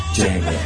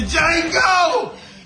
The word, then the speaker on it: Django: The Django! You black son of a bitch! – The D is silent, nigga.